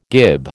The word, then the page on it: gib: American English